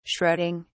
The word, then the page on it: shredding: British English